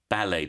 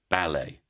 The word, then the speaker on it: ballet: Okay, so first we have ballet dancer, ballet. Then we have belly dancer, belly. So you can hear the difference in the sounds of the vowels. Ballet, belly.